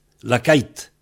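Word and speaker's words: lakaet: Et donc, on a laka-et, lakait. On prononce lakait. L-A-K-A-E-T. C'était, on prononce comme si c'était I-T, en fait. Lakait. Mais, ça dépend des endroits. Parce qu'il y a des endroits où on dit lakait.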